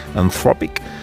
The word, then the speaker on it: Anthropic: El Mundo destaca la presencia junto al Papa ayer de Christopher Ola, que es el cofundador de la tecnológica Anthropic, me ha dicho Marta, se dice así, Anthropic, que está embarcada esta compañía en la poco disimulada tarea de ser vista como la IA buena.